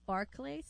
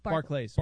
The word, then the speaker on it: Barclays: Is it Barclay? – Barclays. – Barclays. – Like, but is it pronounced Barclays Center? – No, Barclays. No? Barclays. Okay.